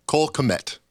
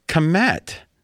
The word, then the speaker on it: Kmet: Cole Kmet. – Kmet.